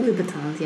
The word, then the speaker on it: Louboutins: I got Louboutins. Is that how you pronounce it? – Louboutins, yeah.